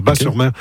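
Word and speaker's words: Batz-sur-Mer: Oui, Bat, on prononce Bas, le T ne se prononce pas, Bas-sur-Mer.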